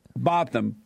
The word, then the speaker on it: Botham: Is it Botham? – I think that's how his brother pronounced it. – Botham.